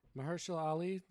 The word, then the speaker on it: Mahershala Ali: Mahershala Ali. Did I say that right? – You nailed it, dog.